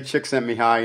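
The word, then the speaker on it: Csikszentmihalyi: I actually have to look at that written down when I say his name until I pattern that into my mind and I can say chick sent me high in the flow state myself.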